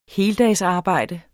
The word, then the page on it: heldagsarbejde: ˈheːldas-